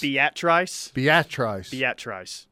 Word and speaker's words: Beatrice: Well, someone says it's not pronounced like that on the text line. Here, there's the text right there. See if you can get that one. – Be at Trice. – It's a Nebraska thing, is what the text says.